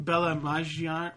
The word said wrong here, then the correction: Bella Maggiore: Maggiore? – Maggiore. Bella Maggiore.